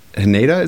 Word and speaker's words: Haneda: Narita and Haneda, is that how it's pronounced? H&D. Make sure you type that one correctly, Matt. Yep. – You're correct.